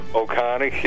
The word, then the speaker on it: Ohtani: I'm not saying he was wrong, but my gosh, that, how do you say his name? Ohsaki? – Shohei Ohtani. – Ohtani, yeah.